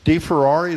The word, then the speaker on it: DeFerrari: Here at the library, the newest art installation, the central staircase of DeFerrari, is that how you say it? – That's how you say it.